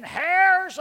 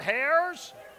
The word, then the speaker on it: heirs: He said, church, today I want to talk about being heirs of God. I want to stand up and say, it's heirs, not hairs. Silent H, come on.